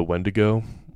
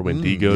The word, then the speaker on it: Wendigo: It actually, it made me think as you're going through the story initially, a very fun bit of folklore of the Wendigo or Wendigo, yeah, depending on how you want to pronounce it.